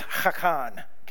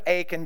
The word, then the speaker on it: Achan: In Hebrew, I'll butcher it, but it's Hakan, okay? We'll stick with Achan because, well, we live in the county, okay? So we'll go with that title. It's easier to say.